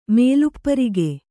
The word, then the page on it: ಮೇಲುಪ್ಪರಿಗೆ: Kannada